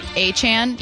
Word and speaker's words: Achane: I don't know if it's Achane. He wanted to change the pronunciation. – It's Achane.